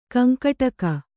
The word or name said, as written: ಕಂಕಟಕ